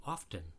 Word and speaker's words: often: How often do you say often? Lots of people pronounce the T in this word, even though the T sound fell out hundreds of years ago.